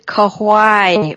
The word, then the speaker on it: Kauai: And we're back, and I just learned that I very badly mispronounced the coffee Joan is drinking. It's roasted Kauai blend, and Kauai is an island in Hawaii.